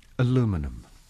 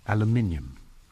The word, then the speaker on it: aluminium: You would say aluminum, and we would say in Britain aluminium.